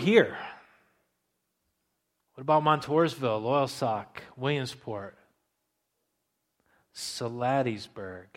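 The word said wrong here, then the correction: Salladasburg: Did I say it right? I butcher it usually. It's close enough, I think, right? No, not even, huh? Saladesburg. The towns that are out west of here, God has a plan there too.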